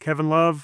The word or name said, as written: Kevin Love